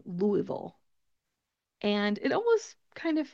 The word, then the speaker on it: Louisville: You're in Louisville, Kentucky. That's how we pronounce it.